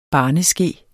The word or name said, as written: barneske